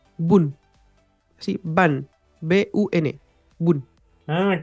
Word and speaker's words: Bun: no sé si escucharon sobre el nuevo framework se llama Boon, se escribe B-U-N.js.